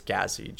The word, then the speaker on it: Gahzi: With a J and then a G-O-G-U-L. – Nice. You can usually find me on it's Gazi, G-A-H-Z-I.